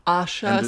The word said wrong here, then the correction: Asa Butterfield: It's Asa Butterfield.